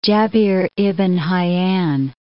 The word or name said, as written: Jabir ibn Hayyan